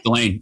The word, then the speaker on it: Delane: Did I say that correctly? – Delane. Yeah.